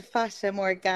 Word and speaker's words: Fata Morgana: So it's called Fata Morgana.